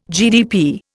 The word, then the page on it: GDP: Indian